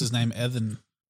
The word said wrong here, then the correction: Ethan: Evan? Evan Hawke. – Evan. – Most definitely Ethan Hawke.